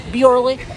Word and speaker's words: Bjorley: If they're, like, insistent, like, how would you pronounce it? Like, they really push me on it. Then I'm like, Bjorley.